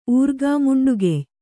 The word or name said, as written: ಊರ್ಗಾಮುಂಡುಗೆಯ್